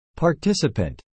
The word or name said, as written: participant